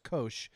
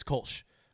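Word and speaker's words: Kölsch: corrected me and said it's pronounced Kosh with a oh. long O.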